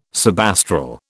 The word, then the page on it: subastral: British English